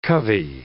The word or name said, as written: covey